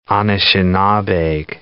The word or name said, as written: Anishinabeg